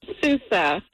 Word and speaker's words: Sousa: Sousa. S-O-U-S-A. – S-O-U-S-A.